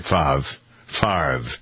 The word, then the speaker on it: Favre: That's the way you pronounce his name, right? Favre.